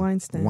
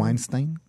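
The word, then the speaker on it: Weinstein: איך מבטאים את השם שלו? ויינשטיין. ויינשטיין? כן.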